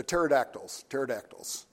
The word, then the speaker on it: pterodactyls: I didn't know how to spell it, so I looked up how to spell it, and I learned there's a P on the front of it, but the P is silent, so it's P pterodactyls.